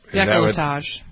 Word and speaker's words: décolletage: We've had, what did you, I didn't pronounce it right, décolletage? – Décolletage.